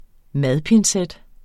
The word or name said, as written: madpincet